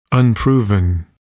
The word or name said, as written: unproven